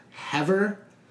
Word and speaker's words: Hever: And it concerns the historical, I believe it's pronounced Hever, H-E-V-E-R Castle.